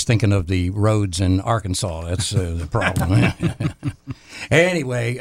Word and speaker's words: Arkansas: Per the state code, the only acceptable pronunciation is in three syllables, Arkansas. – Arkansas? – The final S is silent, the A in each syllable with the Italian sound, and the accent on the first and last syllable.